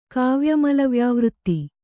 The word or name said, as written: ಕಾವ್ಯಮಲವ್ಯಾವೃತ್ತಿ